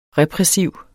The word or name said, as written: repressiv